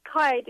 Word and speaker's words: Keidi: It's Keidi. It's just like Heidi, but with a K.